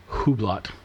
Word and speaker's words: Hublot: So the other part of this rumor was the head of Hublot, which is written like Hublot, but it's Hublot.